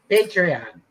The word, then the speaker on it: Patreon: And am I saying this right? Patron? Is that correct? – Patron. Patreon.